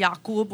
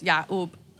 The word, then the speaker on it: Yacoub: Like, some people might say my name instead of Yaqub, which is with a cough, is Ya'ub.